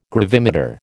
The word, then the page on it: gravimeter: American English